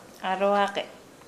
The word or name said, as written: aloage